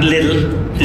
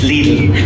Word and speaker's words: Lidl: To thank Robert Ryan for, now it's not little, it's not Lidl, it's Lidl. It rhymes with needle.